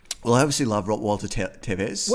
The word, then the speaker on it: Walter Tevis: I'm really glad I got that pronunciation right. Yeah. I was really, really on the fence there. – I kind of cued you in with by saying Tevez.